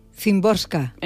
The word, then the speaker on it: Szymborska: Ver, ¿cómo lo pronuncias Szymborska. – Tú? Szymborska. – Entiendo yo que sí, por lo como yo he escuchado a la gente pronunciarlo.